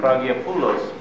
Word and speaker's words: Cragiopolis: With the hard G. – Cragiopolis. – Cragiopolis, yes. Cragiopolis. I'll go for that. Cragiopolis it is.